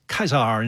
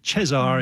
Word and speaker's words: Caesar: You know, it's pronounced Caesar in classical Latin and Cesar in medieval or church Latin. But, of course, we say Caesar, of course.